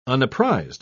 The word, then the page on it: unapprised: British English